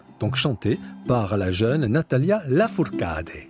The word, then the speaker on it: Natalia Lafourcade: Natalia Lafourcade, il faut le prononcer comme ça, est une chanteuse qui nous vient du Mexique.